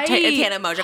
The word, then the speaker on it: Mongeau: Tana Mongeau.